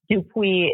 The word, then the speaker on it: Dupuy: Dupuy is spelled D-U-P-U-Y as in yellow.